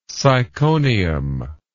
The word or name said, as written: syconium